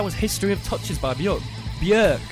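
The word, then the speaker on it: Björk: But before that, we're going to go for a song by Bjork.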